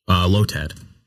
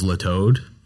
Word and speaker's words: Lotad: I thought Lotad. I thought there was another O, and I thought it was Latoad, like, dude, for the longest time.